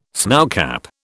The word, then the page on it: snowcap: British English